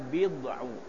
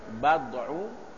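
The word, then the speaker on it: bid'u (بضع): Bikasril ba, bacanya bid'u. Wa qad tuftah, terkadang dengan dipatahkan itu ba-nya, bad'u, bid'u.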